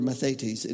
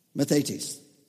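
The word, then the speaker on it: mathetes (μαθητής): And it's the word mathetes. And it's spelled M-A-T-H. Everybody say M-A-T-H. What do you see there? Math. And on the end of that, put E-T-E-S, mathetes.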